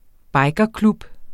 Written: bikerklub

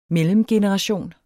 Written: mellemgeneration

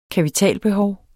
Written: kapitalbehov

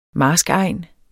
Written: marskegn

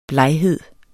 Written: bleghed

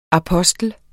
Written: apostel

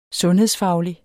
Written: sundhedsfaglige

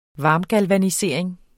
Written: varmgalvanisering

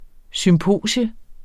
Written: SYMPOSIUM

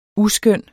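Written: USKØNNE

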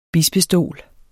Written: bispestol

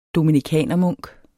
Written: dominikanermunk

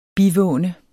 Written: bivåne

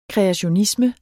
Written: kreationisme

